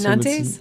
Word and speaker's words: Nantes: Brand New Beirut, that was Nantes, N-A-N-T-E-S. – I assume Nantes?